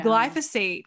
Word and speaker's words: glyphosate: Oh, glyphosate. Am I saying it wrong? – Glyphosate. I think that's how you pronounce it.